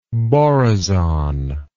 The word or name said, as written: borazon